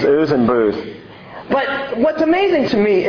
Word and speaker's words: Buz: Verse 23, by the way, you think Uz and Buz is bad? That's actually not the correct pronunciation. You ready for this? It's really Uz and Booz.